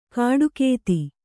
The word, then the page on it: ಕಾಡುಕೇತಿ: kāḍukēti